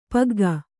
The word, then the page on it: ಪಗ್ಗ: pagga